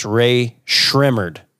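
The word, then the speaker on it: Rae Sremmurd: That's wrong. It's Ray Shrimmered. – Well, that was close it's enough. – It's ear drummers backwards.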